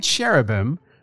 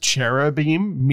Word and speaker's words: cherubim: That I-M at the end, if you were pronouncing it more correctly, would sound like an E-M, not an M. So instead of seraphim, we would say seraphim. And instead of cherubim, we would say cherubim.